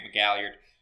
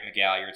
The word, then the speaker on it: McGalliard: My name is Grant McGalliard. Find me on Twitter at Grant McGalliard. Spelled like it sounds with all those vowels.